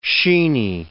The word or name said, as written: sheeny